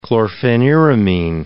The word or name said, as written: chlorpheniramine